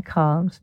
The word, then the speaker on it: cahv: Everyone, and I mean everyone, who knows, pronounces C-A-V-E as cov, and she spells it C-A-H-V, with a short A sound, and definitely never ever cave with a long A sound, as in where Neanderthal people had lived.